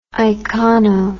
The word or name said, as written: icono